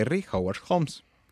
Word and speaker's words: Harry Howard Holmes: no quiso aceptar la culpa, se registraría en el hotel de Chicago con el nombre de Harry Howard Holmes. ¿Lo dije bien? – Sí.